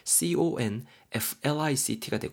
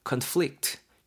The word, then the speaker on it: conflict: 똑같은 단어인데 요노마가 발음이 conflict 이렇게 강세가 뒤에 들어가고 conflict 이렇게 발음이 되면은 뭔가 좀 동사로 활용이 되는 거고요. 지금 제가 처음에 발음한 거맨치로 conflict, conflict 강세가 앞에 들어가고 conflict 이렇게 발음하면은 뭔가 좀 갈등이라든가 충돌 이런 느낌으로 어, 여러분도 활용할 수 있다라는 거 꼭 구분해 주셔야 됩니다.